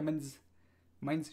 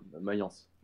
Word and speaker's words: Mayence: Qu'en penses-tu donc euh, du joueur suisse là, qui vient de signer en Allemagne aujourd'hui, Fernandez, qui a signé à Mainz Mainz, je sais pas comment on prononce. – Mayence. – Mayence, Mayence.